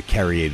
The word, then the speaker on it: Carrier: Carrier, some people Carrier, but I go with the Carrier, the French pronunciation.